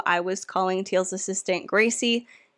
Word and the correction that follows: Grazie: That's how I assumed her name was pronounced because nobody said it out loud. But in episode 3, we hear Teal calling her Grazie, pronouncing it that way. And so throughout the rest of the time talking about her, when I refer to her, I will say Grazie.